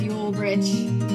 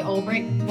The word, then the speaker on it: Ulbrich: As for the last name, here's the hitch looks like it should be pronounced Ulbrich. But we say Ulbrich, though there's no K in sight. We were German, we would say it right.